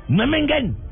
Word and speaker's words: Memmingen: ¿cómo se pronuncia? – Memmingen. – Ya. Se pronuncia como acaba de decir Sí, señor, lo que pasa es que el señor tiene es alemán, yo no puedo hacerlo.